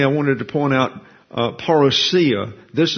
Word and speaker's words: parousia: But according to the New Oxford American Dictionary, it's pronounced parousia.